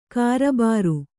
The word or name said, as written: ಕಾರಬಾರು